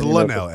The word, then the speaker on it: Lynnell: My name is Lynnell. – Lynnell! Yes! – The way it's spelled, I found it out differently.